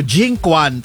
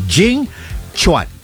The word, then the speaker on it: Jing Quan: Si Ambassador Jing, sa kung babasahin mo, Jing Quan, pero ang pronunciation pala nun ay Jing Chuan.